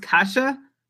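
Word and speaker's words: Kase: As far as I know, it's pronounced Kasha.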